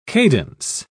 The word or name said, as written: cadence